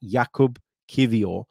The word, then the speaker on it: Jakub Kiwior: So it is pronounced like that, Jakub Kiwior. The W is pronounced as a V.